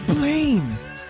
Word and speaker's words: Blaine: It's Blaine. – Because it rhymes with plane.